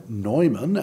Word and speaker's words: Neumann: And the two famous Redemptorists in America are, of course, St. John Newman, or Neumann, as it's pronounced in certain parts of our country,